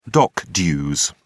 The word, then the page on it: dock dues: British English